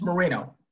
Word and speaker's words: Moreno: Did I pronounce that right? – It's Moreno.